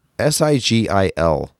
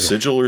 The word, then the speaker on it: Sigil: And if you want more Doom, SIGIL, how is that pronounced? – Yes. – Okay. – Yes. It's either Sigil or Sigil, depending on where you're coming from.